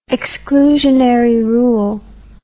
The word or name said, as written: exclusionary rule